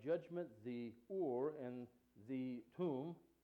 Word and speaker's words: Thummim: Now, these stones were called, and I know that when you read it phonetically in your scriptures, it looks like and Tumen, but it's pronounced Or and Tum.